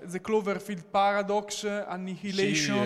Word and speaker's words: Annihilation: Secondo me Annihilation, l'ho detto bene? – Sì, eh, questo l'hai detto bene.